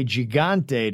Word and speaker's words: gigante: When you say gigante, don't say the G like G.I. Joe. Say it like my name, Jose.